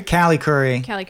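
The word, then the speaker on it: Callie Khouri: Carrie Curry? The director? – Callie Curry.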